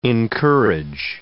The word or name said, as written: encourage